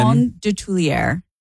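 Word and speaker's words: Coton de Tulear: C-O-T-O-N. – Coton? – C-O-T-O-N. – Coton de Tulier. – How the hell do you spell that? – Of Tulier. D-E. T-U-L-E-A-R.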